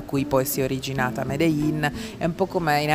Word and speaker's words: Medellín: anzi si chiama Medellin, ecco l'ultimo giorno si imparano sempre le cose più importanti, la pronuncia esatta è Medellin.